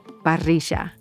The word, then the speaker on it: parrilla: In Argentina, often the double L's and Y's are pronounced with a J sound, as in parrilla.